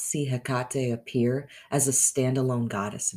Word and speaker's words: Hecate: Her name, although pronounced in various ways throughout history, is genuinely pronounced either Hecate or Hecate, with an emphasis on the middle.